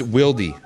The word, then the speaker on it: Wilde: Isn't it spelled Wilde? – Yeah, but why would you think that's wild if there's an E at the end? – Because, I mean, a silent E is very prevalent in the English language. – So, okay. – Like, if someone's name was Earl with an E at the end, you wouldn't call him Early.